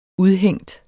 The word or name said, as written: udhængt